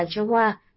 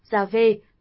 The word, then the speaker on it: YHWH: thường được đọc là Jehovah, tiếng Việt, Yahweh, tiếng Anh, The Lord God.